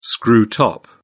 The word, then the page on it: screw top: British English